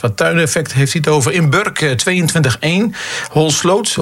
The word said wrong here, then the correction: Holsloot: of Holsloot moet ik eigenlijk zeggen, de klemtoon, 21,8.